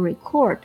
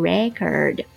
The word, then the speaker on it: record: 注意一下 record 做名词的时候读音啊，不读 record, 读 record.